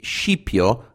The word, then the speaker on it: Scipio: By the way, I've pronounced it Scipio, as I heard from Mike Duncan and other history podcasts, whereas in Italian it would be Scipio, abbreviation of Scipione.